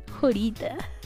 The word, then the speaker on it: Jorita: Jorita es que se escribe Jorita como si dijeras una hora pero chiquita Jorita bueno, ese no es el punto.